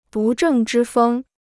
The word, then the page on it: 不正之风: bù zhèng zhī fēng